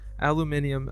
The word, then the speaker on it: aluminium: Aluminium. I'm glad that we're using the British pronunciation.